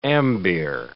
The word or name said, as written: ambeer